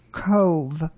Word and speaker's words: Cobh: However, we did go to Cove, which is spelled C-O-B-H.